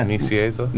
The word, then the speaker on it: Nicieza: Is that how you say his name? – Yeah, Nicieza. – I never knew. – N-I-C-I-E-Z-A. I'm guessing.